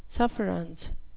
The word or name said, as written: sufferance